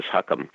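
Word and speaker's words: Chu Chem: He has a very funny story about a show called Huckum that's actually spelled Chew Chem, but the real pronunciation is Huckum.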